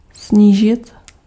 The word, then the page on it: snížit: /ˈsɲiːʒɪt/